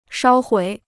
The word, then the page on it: 烧毁: shāo huǐ